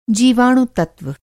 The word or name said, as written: जीवाणु-तत्त्व